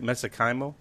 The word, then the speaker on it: mesenchymal: Am I saying this right? Mesenchymal? – Yeah, it's pronounced so many ways, but the way I pronounce it, mesenchymal stem cells, or MSC.